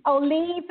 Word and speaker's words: Levy: Oh, it, by the way, just so you know, it's Levy. – Levy. And, my bad. – Yeah. That's it's right. Levy. You know, there's three different ways to pronounce that name, so it's really fine.